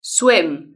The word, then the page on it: swim: British English